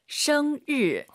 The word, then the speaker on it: 生日: 정말 많은 분들이 生日, 生日, 르. 한글에 르 있잖아요. 그게 들리면 안 되세요. – 没错. – 生 하시고 입 벌린 상태에서 生日. 혀만 천장에 대면서 드럭 生日.